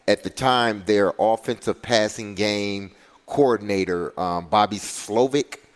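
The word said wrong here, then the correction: Slowik: Slowick. That's how you pronounce his name.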